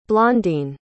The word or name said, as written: blondene